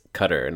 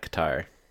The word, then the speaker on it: Qatar: Apparently, it is pronounced Qatar, not Qatar.